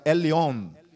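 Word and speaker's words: Elyon: Ali no, na língua cananeia, o Deus a quem Melquisedeque servia chamava-se Eliom, na língua cananeia, a língua que se pronunciava o nome de Deus era Elion.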